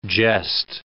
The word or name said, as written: jest